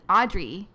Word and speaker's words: Adri: The thing is that it's spelled like Adri because it's short for Adriana, obviously. – Right. – But I'm just different because my family grew up calling me Audrey. – Yes. – Because in Spanish it's Audrey. – Adriana. Audrey. – Oh. – So they would call me Audrey.